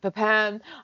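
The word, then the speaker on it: Pépin: and Jacques and Julia with Jacques Pepin, which is a Pepin, or I don't know how you say it. – Pepin, which is an absolute revelation.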